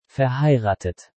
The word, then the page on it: verheiratet: German